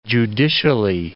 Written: judicially

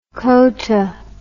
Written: kota

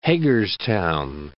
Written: Hagerstown

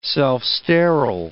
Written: self-sterile

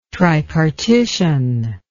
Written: tripartition

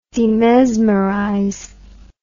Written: demesmerize

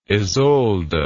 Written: isolda

